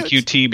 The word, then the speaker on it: Qt: The QT framework, which I know is pronounced cute. Everyone always emails me that. So I say QT